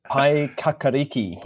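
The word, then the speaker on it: Paekākāriki: Pai Yakaiki? – Pai Kakariki.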